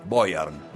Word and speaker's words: Beuern: ¿Cómo se escribe Boyern? – Boyern, B-E-U-E-R-N. Beuern, Boyern.